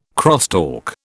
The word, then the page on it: crosstalk: British English